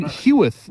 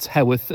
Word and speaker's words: Heworth: We are here in Heworth, not Heworth, as I've been pronouncing it for the past few years.